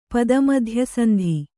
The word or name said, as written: ಪದಮಧ್ಯಸಂಧಿ